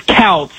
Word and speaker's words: Kouts: Is it Coates or Coots? How do you say that? K-O-U-T-S. – It's Couts.